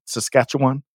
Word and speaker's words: Saskatchewan: Saskatchewan? – Saskatchewan, yeah.